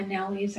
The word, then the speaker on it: nawi: My nawis are open. I remember that term because of the word Maui. It rhymes with nawi.